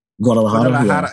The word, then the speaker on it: Guadalajara: Guadalajara.